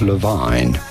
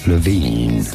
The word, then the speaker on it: Levine: Once you know, it's not Levine, but rather Levine.